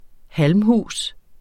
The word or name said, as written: halmhus